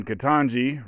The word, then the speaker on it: Ketanji: And then Ketanji rhymes with Jumanji.